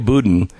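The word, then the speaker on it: Boudin: They've refused to bat an eye when the son of two weather-ground underground members, Chesse, how do you say his last name? Budin? Yeah, Chesse Budin, who runs the district, who runs for district attorney in San Francisco.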